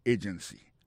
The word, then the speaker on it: agency: Pour mot agency, hein, accent tombé sous premier syllabe là. Agency. Deux autres syllabes qui sont derrière eux, faible.